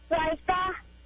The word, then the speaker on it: Ryka: Hi, my name is Raita, and I think the answer is red eye. – What's your name again? – Raita. – Spell it. – R-Y-K-A.